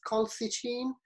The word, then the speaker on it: colchicine: Um, I can never really pronounce this. Uh, it's, I think I missed uh, the L there. It's colchicine.